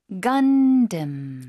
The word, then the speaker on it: Gundam: Gundam. – Gundam. – Gundam. – 앞쪽에 힘주고 뒤쪽에 살짝 약화시켜서. 네. 영어식 발음입니다.